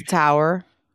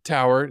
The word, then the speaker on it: thar: T-H-A-R. How do you say that again? That one's very popular at zoos. – It's a tower. – Tower.